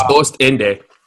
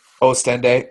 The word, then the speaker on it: Ostende: They're both Belgian internationals, and Jordan Lukaku moved to Lazio from Ostende. I think I'm pronouncing that right. I don't know. – I think it's Ostende. – Ostende. Oh, okay. So instead of ooh, it's O.